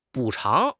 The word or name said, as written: 补偿